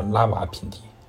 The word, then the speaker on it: 拉瓦尔品第: 中国人读的时候呢，都把它叫做拉瓦品地，那个耳基本上不发音啊。在拉瓦品地，啊，拉瓦品地位于伊斯兰堡西南十二公里，人口只有一百四十一万。